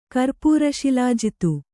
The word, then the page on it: ಕರ್ಪೂರಶಿಲಾಜಿತ್ತು: kannada